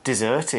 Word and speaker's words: dessert: Dessert is spelled D-E-S-S-E-R-T, dessert. Don't spell it like desert. It's an easy mistake to confuse the two when you're writing. This is a dessert, okay? Pronounce it that way.